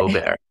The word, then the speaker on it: Hubert: And also the actor who plays the character, you pronounce it Hubert as in the French way, or I'm not so sure how to pronounce his name.